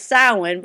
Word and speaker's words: Samhain: So the way that the correct pronunciation is Samhain, but I know you spell it like Samhain.